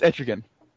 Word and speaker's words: Etrigan: It's Etrigan.